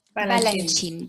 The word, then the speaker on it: Balanchine: Y bueno, esta producción eh, es de George Balanchine, eh, espero que así se, pronuncie. Balanchine. y pues bueno, perdón. – Balanchine, George Balanchine, sí.